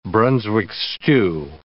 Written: Brunswick stew

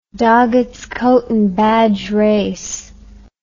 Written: Doggett's Coat and Badge Race

